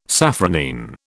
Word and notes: safranine: British English

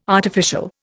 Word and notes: artificial: Indian

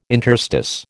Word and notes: interstice: American English

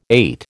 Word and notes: eight: American English

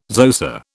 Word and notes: Xhosa: British English

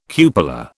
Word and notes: cupola: British English

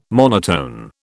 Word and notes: monotone: British English

